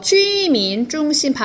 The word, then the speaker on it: 住民中心: 우리나라 말은 주민센터에서 살주로 쓰는데, 중국인은 거주자라고 해서 주민중심, 발음이 JU예요.